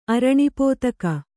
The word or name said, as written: ಅರಣಿಪೋತಕ